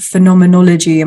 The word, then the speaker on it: phenomenology: and we thought that we'd talk today about phenomenology. Am I pronouncing it right? – Yes. Yeah, it's okay. A bit of a mouthful.